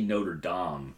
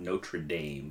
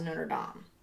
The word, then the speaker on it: Notre Dame: We say Notre Dame, not Notre Dame, because, you know, we're not savages. – Well, I know. I think people say Notre Dame, but I think it's supposed to be pronounced Notre Dame. – It is, because it's French.